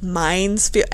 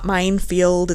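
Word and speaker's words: Mines Field: Like, I don't fucking know what happened, but I dropped the S, and it's not Minefield, it's Mine's Field, and I'm an idiot.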